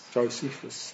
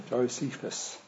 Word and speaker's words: Josephus: Josephus, that's it. Josephus.